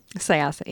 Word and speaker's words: Syosset: George Lutz was in Syosset running some errands. Did I say that right? – Syosset, yeah.